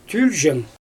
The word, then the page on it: tylʲʒen: Xyzyl